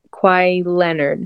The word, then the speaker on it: Kawhi Leonard: And I honestly think it's hard to pick between Vince Carter and Kawhi Leonard.